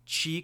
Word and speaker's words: Qi: But what they did do is they made them Qi compatible. Are we deciding to say Qi compatible? – Sure. – Yeah. – We're deciding to say Qi compatible.